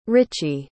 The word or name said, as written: Richie